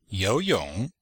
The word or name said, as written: you2yong3